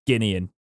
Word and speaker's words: Guinean: it's it's Guinean, isn't it? – Yeah. It's Guinean. I always read that nationality and say it wrong.